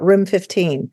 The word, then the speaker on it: RM15: and we call it Room 15, and it's spelled RM15, and that's the name of our podcast, RM15.